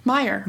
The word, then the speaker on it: Meyer: Sorry, I forgot how to say it already. Meyer. Meyer.